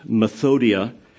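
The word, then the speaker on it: methodeia: West's Word Studies of the Greek New Testament says about the word wiles, which is pronounced methodia, as cunning arts, deceit, craft, trickery.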